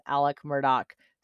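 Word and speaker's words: Alex Murdaugh: There have been huge discussions, multiple threads on how you pronounce his name correctly. And they pronounced it in court as Alec Murdoch. So that's what I'm going to go with.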